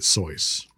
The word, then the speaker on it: Seuss: Or Seuss, as I think some people will allege it is properly pronounced. – I think he pronounced it Seuss.